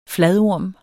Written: FLADORMEN

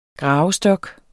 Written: GRAVESTOK

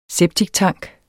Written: septiktank

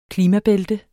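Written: klimabælte

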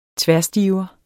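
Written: tværstiver

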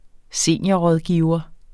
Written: seniorrådgiver

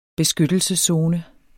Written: beskyttelseszone

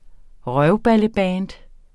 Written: røvballeband

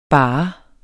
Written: bare

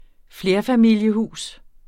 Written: FLERFAMILIESHUS

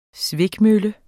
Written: svikmølle

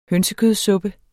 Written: hønsekødssuppe

